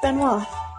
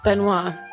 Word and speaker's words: Benoit: And Valerie let us know that uh, the name is French and pronounced Benoit. Benoit. I knew Benoit sounded wrong.